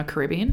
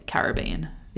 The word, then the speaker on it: Caribbean: And I also looked up whether it was Caribbean or Caribbean because I couldn't remember which one was the right one. – Yeah. – We say Caribbean.